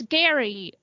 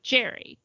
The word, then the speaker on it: Gerry: It's Gary, but it's like G-E-R-R-Y, which I would assume was Jerry, but he doesn't pronounce it that way.